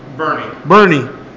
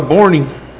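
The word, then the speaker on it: Boerne: Calhoun versus, how do you say that? Bernie or Bornie? – Bernie. – I like that fucking little town. – Bjorn? – I always call it Bornie.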